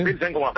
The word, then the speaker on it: Pilsen: Pilsen como a cerveza. Fácil de pronunciar, hombre.